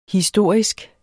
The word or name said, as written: historisk